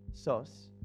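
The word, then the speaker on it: Sós: SOS. Además, SOS con acento en la O.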